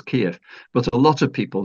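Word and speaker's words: Kyiv: Well, yeah, Kiev is the Russian and Kiev is the Ukrainian pronunciation.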